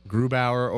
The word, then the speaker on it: Grubauer: I was asked, who do you play, Grabauer or... Grubauer or Holtby.